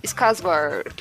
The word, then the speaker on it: Skarsgård: E esse A com a bolinha quer dizer que se pronuncia com um O. Então, mais ou menos seria Skarsgård.